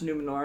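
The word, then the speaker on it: Númenor: It's just Numenor. I mean, again... – Numenor! – I can't trill my R's, but it would sound like that.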